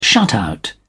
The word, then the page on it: shutout: British English